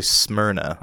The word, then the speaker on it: Smyrna: You're just, how do they, like, pronounce Smyrna? – It's actually Smyrna.